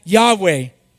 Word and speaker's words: Yahweh: However, in the 19th and 20th centuries, biblical scholars began to pronounce Yodhead Wadhead as Yahweh.